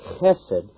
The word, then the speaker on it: chesed: Several times, four particularly, the term hesed is used, or pronounced chesed in Hebrew.